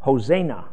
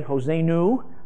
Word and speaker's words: Hosanna: Okay, now here's how you actually pronounce this name. Ho, Zay, Na. And the accent's on the Zay. Hosanna.